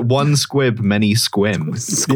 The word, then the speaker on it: Sequim: It's spelled like sequim, like a sequimed dress might be. And then minute one of joining the call, I was assaulted with the knowledge that it's pronounced squim.